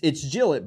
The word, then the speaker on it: Gillett: Jeff from Gillett, Pennsylvania writes, it's Gillett, by the way. – Not Gillette. – Jeff explained this to us. So it's spelled like Gillette, the razor company, yeah, but without the E on the end. So he says it's Gillett.